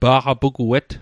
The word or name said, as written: pa'qapuguet